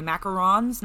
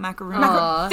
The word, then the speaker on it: macaron: She gave me a box of, what are they, macarons? Not macaroons. – Thank you for saying it correctly.